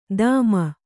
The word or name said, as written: ದಾಮ